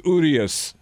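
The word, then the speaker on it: Urías: How come I can pronounce it? It's Urias. – I've heard it pronounced like five different ways. – People are saying Urias. – How did Joe Buck pronounce it? – Well, here's how the Spanish broadcast pronounced it. – Julio Urias, Dodgers on 100. – Urias. – U is a U.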